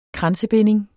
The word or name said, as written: kransebinding